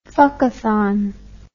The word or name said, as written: fuckathon